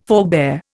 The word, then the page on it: forebear: Indian